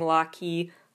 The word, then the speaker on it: Locky: Secret Number, we've talked about their fandom name before, being Locky, Lock and Key, but pronounced like Rocky, but it's spelled Locky, which is fine, but I wanted something like the Codebreakers or something more baddie.